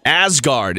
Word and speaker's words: Asgard: Asgard. Is that how you say it, Kevin? – Yes. Oh, my God. You got it right.